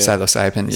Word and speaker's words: psilocybin: Like, there's very little way you pronounce it like that, because I think that's the proper way to say it too. – Psilocybin.